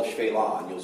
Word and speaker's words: Shephelah: It's called the Shephelah, or Shephelah, if you were to pronounce it literally in English, it's Shephelah.